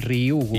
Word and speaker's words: Ryugu: Ryugu. És molt fàcil, com una I, Ryugu.